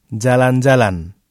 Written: jalan-jalan

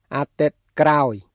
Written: អាទិត្យក្រោយ

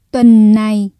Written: tuần này

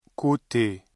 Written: côté

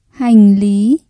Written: hành lý

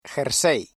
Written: jersey

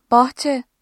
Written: bahçe

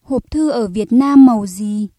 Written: màu

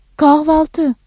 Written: kahvaltı

